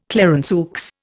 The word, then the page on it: Clarenceux: Indian